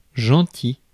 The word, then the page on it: thoughtful: American English; /ˈθɔt.f(ə)l/